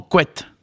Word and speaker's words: coat: Par exemple, dans la toponymie, on le trouve souvent sous la forme quat, C-O-A-T. Par endroit également, il faut signaler que, par exemple, dans le Vannetais, il se prononce, et d'ailleurs il s'écrit souvent, quet, c'est-à-dire que le A devient E.